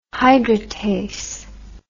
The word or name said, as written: hydratase